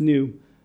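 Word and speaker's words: nu: One of them is called the movable new, and new is N-U. It basically, the Greek letter called new, it's the letter N, pronounced new, can occur at the end of certain words if they precede a word that starts with a vowel.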